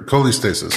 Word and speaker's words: cholestasis: this coleostasis, is how you pronounce it? Coleostasis? Uh, sure. Cholestasis. Cholestasis. Uh, Co- coleostasis.